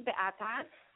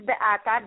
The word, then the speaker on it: Beata: My name is Beata. – I'm sorry. Can you say that again or spell it for us even would be better. – My name is Beata, B-E-A-T-A.